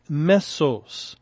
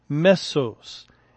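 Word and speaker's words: mesos (μέσος): And the Greek word translated as midst is Strong's number 3319, and we could pronounce it mesos, mesos.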